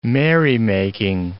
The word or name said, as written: merrymaking